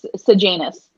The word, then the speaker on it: Sejanus: Is it Sejanus? – Sejanus. – Is it today? – Sejanus.